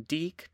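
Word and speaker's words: Deke: In part one, we looked at a brief history of Delta Kappa Epsilon, which I will be calling DEEK because that is what I found in my sources as it is abbreviated and that's how it is pronounced.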